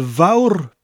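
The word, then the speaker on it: fawr: Now, hoyl vaur, the vaur bit is pronounced like the English word vow, but with that little bit of a rolling R at the end of it.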